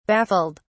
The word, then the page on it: baffled: British English